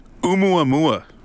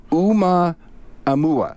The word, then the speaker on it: Oumuamua: The Oumuamua. – I think you're right. – I think so, too. – Oumuamua.